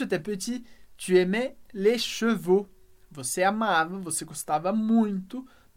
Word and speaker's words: chevaux: E aqui atenção para a pronúncia de chevaux. Chevaux, cavalos. Um cavalo, a gente diz cheval, un cheval, um cavalo. Os cavalos, nesse caso, les chevaux, o no final.